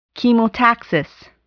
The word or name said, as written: chemotaxis